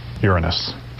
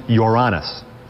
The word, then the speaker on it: Uranus: Strictly speaking, it's pronounced Uranus is the pronunciation.